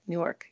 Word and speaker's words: Newark: Newark.